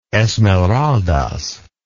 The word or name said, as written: Esmeraldas